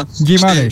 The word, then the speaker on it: Guimarães: Guimarães, nasıl okunuyor çok özür dilerim bilmiyorum ama Guimarães.